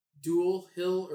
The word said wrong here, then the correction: Dulé: or D-U-L-E with... – Dulé Hill.